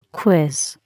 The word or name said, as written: quiz